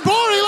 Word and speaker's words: Gborie: The G is silent on Twitter. Cool Guy Jokes 87 on Instagram. David Gborie, ladies and gentlemen.